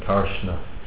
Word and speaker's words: Karsna: K-A-R-S-N-A. Karshna.